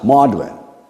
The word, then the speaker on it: Magdalene: Just like we learned last week, Magdalene was pronounced Maudlin.